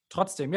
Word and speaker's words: Trotzdem: All right, so the last one, it's a harder one to pronounce. Trotzdem? – Trotzdem, yeah.